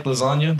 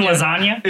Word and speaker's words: lasagna: You didn't realize it because you didn't know that there was a G in lasagna? – Is silent. – Oh, God. – You didn't know it was silent? – See? – You guys didn't... Okay, stop. – No, we knew the G was silent.